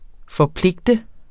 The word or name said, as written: FORPLIGTEDE